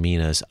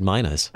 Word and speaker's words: minas: And thanks so much for saying minas. I misread it earlier. I mispronounced it. I knew what it was about, but I think I read a whole parable about birds earlier because I said minas.